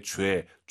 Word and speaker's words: 觉得: 주에도 아니고 제유이. 3월부터 함께 하신 분들은 배운 적 있습니다. 너의 죄, 죄라고 발음하시기 바랍니다. 이성경성.